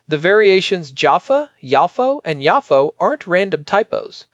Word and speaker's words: Yafo: Yafo is closer to the modern Hebrew pronunciation and follows a more academic transliteration convention.